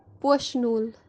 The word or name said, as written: poshinool